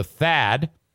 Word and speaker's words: Thad: Say hello to Thad. That's T-H-A-D, rhymes with fad.